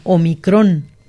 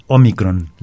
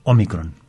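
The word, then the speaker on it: Ómicron: En este caso la pronunciación es Omicron. Entonces Omicron. – Lleva acento, pero en la primera O, no en la última.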